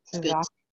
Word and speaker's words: tzatziki: I don't know if I ever pronounced that correct, right? Tzatziki sauce? – Yeah, that's good stuff.